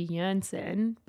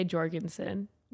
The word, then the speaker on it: Jorgensen: Well, the proper way would be Jorgensen, but we say Jorgensen.